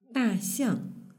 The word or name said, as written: daxiang